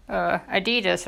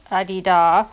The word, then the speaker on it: Adidas: I've got Adidas, or as it's supposed to be pronounced, Adidas.